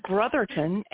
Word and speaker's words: Brotherton: My name is Brotherton, and you'd be surprised. I think it's pretty simple to pronounce. It looks like brother with a ton on the end. And that's how it's pronounced.